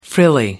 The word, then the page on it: frilly: American English